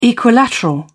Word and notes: equilateral: British English

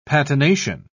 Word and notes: patination: British English

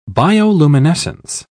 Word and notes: bioluminescence: British English

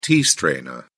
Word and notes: tea-strainer: British English

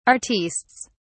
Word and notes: artistes: British English

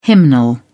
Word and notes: hymnal: British English

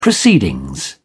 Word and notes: proceedings: British English

